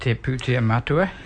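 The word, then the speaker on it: Te Pūtea Matua: Reserve Bank of New Zealand, Putia Matua, is that how you pronounce it? – Te Putia Matua, yeah.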